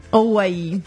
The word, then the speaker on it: Hawaii: Hawaii. – Madonna, che cosa tristissima. – Ah, si pronuncia così, eh? Sì, però sì, non certo. Lo diciamo, perché vai, funziona bene Hawaii con mai.